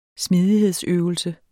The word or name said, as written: smidighedsøvelse